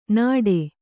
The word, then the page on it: ನಡೆ: kannada